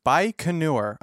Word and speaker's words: Baikonur: Baikonur.